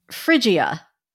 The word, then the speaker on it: Phrygia: And laugh all you want about the name because let me assure you, no one was being frigid in ancient Phrygia. – For those listening, it's spelled with a P-H and there's a Y in there. It's, yeah. – P-H-R-Y-G-I-A, Phrygia.